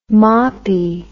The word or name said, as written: moppy